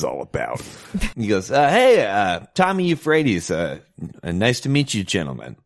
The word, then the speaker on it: Euphrates: Yeah, Euphrates.